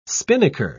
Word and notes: spinnaker: British English

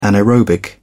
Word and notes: anaerobic: British English

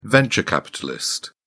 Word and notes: venture capitalist: British English

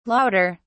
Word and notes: louder: British English

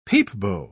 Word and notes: peepbo: British English

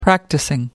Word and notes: practising: American English

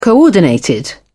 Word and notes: coordinated: British English

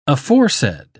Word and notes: aforesaid: English; British English

